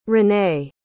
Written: renee

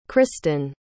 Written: kristen

